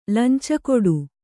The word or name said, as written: ಲಂಚಕೊಡು